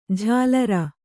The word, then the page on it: ಜಲರಿ: jalari